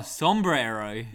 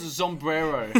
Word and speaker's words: sombrero: What's the hat? – Sombrero. – Yeah, some people call it Zombrero. Zombromino. – Yeah, well, that's not it. – Oh, I don't even know how to pronounce that. That's objectively wrong.